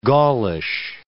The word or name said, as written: Gaulish